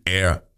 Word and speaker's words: Air: Und die Gruppe, das ist eine französische Gruppe, die heißt Air. Aber die werden wirklich, obwohl es Franzosen sind, es wird ihnen schwer fallen, Air. Englisch Air ausgesprochen.